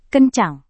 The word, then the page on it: kencang: indonesian